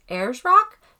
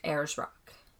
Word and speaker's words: Ayers Rock: So I think she said it's pronounced Ayers Rock.